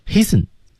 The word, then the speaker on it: hasten: 是 do not t 失去爆破，失去爆破注意 do not t 没有这个音， hasten 中的 t 也是不发音的，大家注意。